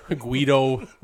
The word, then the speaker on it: Guido: Guido rhymes with weirdo.